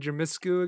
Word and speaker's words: Dumitrescu: Are we simping for Lady Dumitrescu again? – Dumitrescu, yeah. And also Dumitrescu. And also, please say her name right. – Okay. – Say it. – Dumitrescu? – Oh, you did say it right.